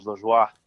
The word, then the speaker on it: Lajoie: Yeah, the French version is Lajoie.